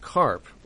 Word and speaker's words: Karpf: Just a note, it's pronounced Karp, like the fish, but it's spelled K-A-R-P-F. So there's a silent F on the end.